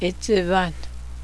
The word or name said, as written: it's a blizzard